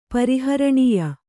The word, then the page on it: ಪರಿಹರಣೀಯ: pari haraṇīya